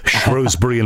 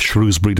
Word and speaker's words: Shrewsbury: Beautiful moonlit nights chugging along the border from Craven Arms and Shrewsbury and places like that, Shrewsbury, depending on how you want to pronounce it.